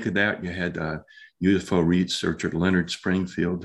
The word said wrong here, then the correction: Stringfield: who's, did I say that right? Springfield? – Yes. – Or Strength? Okay. He, Stringfield, I believe it is.